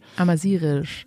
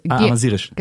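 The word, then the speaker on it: Amazighisch: halb Amazigisch, halb Deutsch. – Amazirisch, also Amazirisch. – Genau, GH wird wie ein R ausgesprochen.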